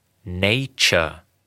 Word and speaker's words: Nature: Das ist Englisch. Die Aussprache ist so: Nature.